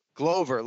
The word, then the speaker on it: Glover: Glover, like four-leaf clover.